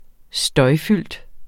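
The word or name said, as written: støjfyldt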